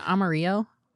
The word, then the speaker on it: Amarillo: Had a pretty stellar outing in his rehab start in Double A Amarillo, and then did a bullpen session yesterday. Reportedly, did you say Amarillo? – Is it? Oh, it's Amarillo. It is the Spanish pronunciation, but yeah, it's Amarillo.